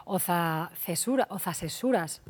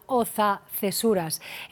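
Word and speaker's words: Oza-Cesuras: Oza Cesuras, no lo he dicho bien, en A Coruña, Oza Cesuras.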